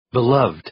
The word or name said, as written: beloved